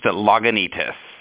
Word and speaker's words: Lagunitas: It's pronounced Lagunitas, a little town that my grandparents lived in for years and years out here in California, so kind of near and dear to my heart.